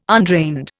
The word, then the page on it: undrained: Indian